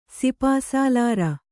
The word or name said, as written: ಸಿಪಾಸಾಲಾರ